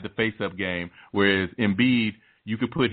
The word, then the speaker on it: Embiid: And Embiid. – Embiid, yeah.